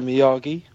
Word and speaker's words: Miyagi: Mr. Miyagi.